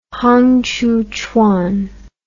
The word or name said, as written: Hong Xiuquan